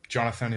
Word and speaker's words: Jonathon: I mean, it's Jonathon in Hebrew, but Jonathon is fine. It's easier for Americans, I guess.